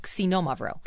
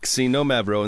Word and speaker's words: Xinomavro: So even though you hear an X, it's not a Z sound. It's a KS. So Xinomavro. – Xinomavro. And that's spelled X-I-N-O-M-A-V-R-O.